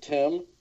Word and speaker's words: Thymme: How would you say it? – I would pronounce it Tim.